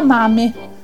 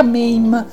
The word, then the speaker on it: Mame: Si tratta di Zia Mame, o Zia Mame, come alcuni lo pronunciano,